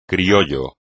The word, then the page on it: criollo: espagnol